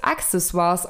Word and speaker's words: Accessoires: Aber das stimmt so nicht. Denn eigentlich wird es Accessoires ausgesprochen, denn äh, Sina, du weißt es vielleicht auch, du hattest, glaube ich, Latein in der Schule.